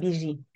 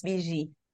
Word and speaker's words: Bibigi: Yes, it's BG. So the G is soft. It's BG. – G. Okay. – It's a Francophone pronunciation.